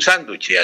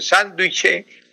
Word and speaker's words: sánduche: Pero en las cafeterías lo escriben sánduche o sándwich. – Sánduche. – Sánduche es la correcta. Sánduche, así como lo estoy pronunciando. Sánduche. Con tilde en la A.